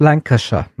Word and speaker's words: Lancashire: Es heißt Lancashire.